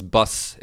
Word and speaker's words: Baz: Yeah, it's Bus. It's Bus. – Oh, okay, well there you go. – Like the thing that you ride on. – Yeah. – Is a bus. It's pronounced that way.